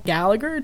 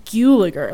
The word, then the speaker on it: Gulager: So Clu says it Guliger. Like he says it rhymes with mule. So it's Guliger, which is Danish, so it makes sense because it's got that weird U.